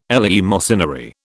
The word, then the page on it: eleemosynary: British English